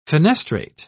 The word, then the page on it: fenestrate: British English